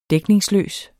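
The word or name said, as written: DÆKNINGSLØS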